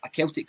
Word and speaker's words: Celtic: So the first Celtic crest was actually a Celtic cross, which, if you tuned in last week, is not with a soft C, it's with a hard C, Gav.